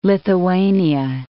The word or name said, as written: Lithuania